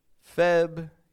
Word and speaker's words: February: There's an R in there, isn't there? Shouldn't there be? An R after the B. Guess what? I don't pronounce that R. For me, that's a silent R. February.